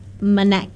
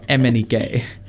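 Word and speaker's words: MNEK: We have Head and Heart by Joel Corey and Manek. – Emenike.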